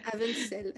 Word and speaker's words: Avensel: Avensel. Il Faut le prononcer un peu à l'allemande ou à, l'anglaise. et euh, effectivement, cette trilogie, euh, c'était ton premier projet publié.